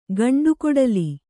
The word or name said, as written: ಗಂಡುಕೊಡಲಿ